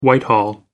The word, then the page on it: whitehall: British English